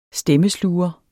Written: stemmesluger

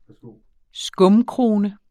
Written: skumkrone

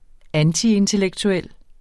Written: antiintellektuel

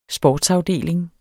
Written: sportsafdeling

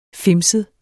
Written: fimset

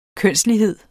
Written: kønslighed

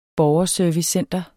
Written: borgerservicecenter

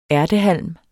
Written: ærtehalm

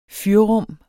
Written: fyrrum

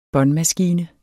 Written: båndmaskine

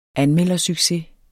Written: anmeldersucces

